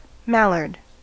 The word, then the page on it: mallard: American English